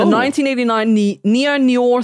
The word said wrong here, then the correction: noir: thriller film. And it's got Al Pacino. – Noir. – Sorry. I'm far yeah. too Australian to pronounce that correctly.